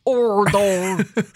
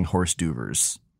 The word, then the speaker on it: hors d'oeuvre: It's pronounced hors d'oeuvre. – I do remember reading in class and saying horse d'oeuvres, reading that word out loud.